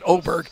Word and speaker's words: Åberg: Hey, man, Ludvig Åberg, yeah, the dot on top of the A means you pronounce it Åberg.